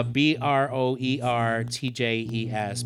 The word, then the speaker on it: Broertjes: I can never pronounce his name. Broertjes. Sure. Uh, B R O E R T J E S, Broertjes.